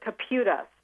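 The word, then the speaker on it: Kaputa: We pronounce it Caputa. So. – Caputa. Okay, yes. Caputa. Let's get it right. – And spelled with a K, K-A-P-U-T-A.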